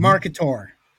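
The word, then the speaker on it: Marketaur: Marketor. Like a dinosaur, but Marketor. Mm-hmm. So it's M-A-R-K-E-T-A-U-R. Did I spell that right? – I think you did.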